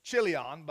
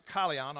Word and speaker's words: Chilion: Secondly, he says, and all that was Chilion's or Achilion's, depends on how which pronunciation you choose to use. Traditionally, I've always said Chilion, but I think Alexander Scorbia may pronounce it Kalion. I'm not sure. It can be either one, actually. The CH can be pronounced either way.